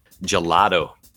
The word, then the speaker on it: gelato: Gelato.